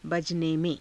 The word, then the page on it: bajane_me^: hindi